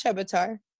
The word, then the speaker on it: Chebotar: It's Chebotar.